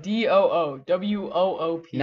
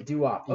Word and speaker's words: doo-wop: Fun fact, doo-wop is spelled D-O-O-W-O-O-P. – Not doo-woop, it is doo-wop.